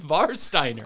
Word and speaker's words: Warsteiner: Yeah, but it's not an English beer. It's pronounced Warsteiner.